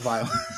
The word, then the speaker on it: Weill: This Kurt is, it's spelled W-E-I-L-L. – Oh, okay. – Yeah, but it's still pronounced Vile.